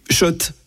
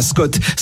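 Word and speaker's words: Schott: Oui, Shot. Et pas Scott. Ça fait des années qu'on prononce mal la marque.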